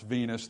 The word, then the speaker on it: vinous: Venous, V-I-N-O-U-S, it's pronounced Venus.